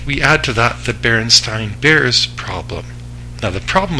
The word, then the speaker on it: Berenstain: I had a teacher come up and say it's spelled Berenstain, S-T-E-I-N, but it's pronounced Berenstain, S-T-A-I-N.